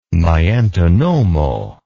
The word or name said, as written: Miantonomo